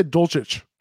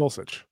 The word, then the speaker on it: Dulcich: I used to say Dulcich, but it's not Dulcich. Right. It's Dulcich.